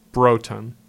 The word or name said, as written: Broughton